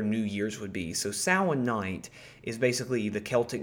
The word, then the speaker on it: Samhain: It looks like Samhain. It's S-A-M-H-A-I-N, but it's pronounced Samhain.